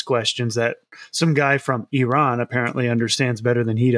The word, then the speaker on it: Iran: Tell you what, Iran got very petty and even criticized the way we say Iran. They say, actually, it's pronounced Iran. Because apparently everybody on earth can have their own accent except Americans. Not allowed to say Iran, yet to say Iran.